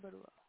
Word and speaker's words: Barua: My name is Tapasya Barua. – How do you spell that? – It's Tapasya. – Mm-hmm. – Then Barua. – But then B-A... – R? – I-U... – R for Russia? – Yeah, B-A-R, yep.